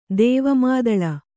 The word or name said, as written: ದೇವಮಾದಳ